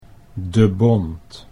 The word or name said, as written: De Bont